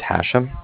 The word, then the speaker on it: Hashem: My first name is Hashem. That's H-A-S. H-E-M, as in mother at the end.